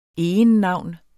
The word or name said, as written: egennavn